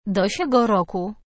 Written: do siego roku

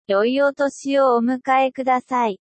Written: omedeto gozaimasu

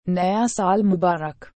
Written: naya sal mubarak

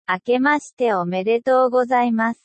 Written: akemashite omedeto gozaimasu

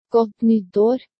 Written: godt nytt ar

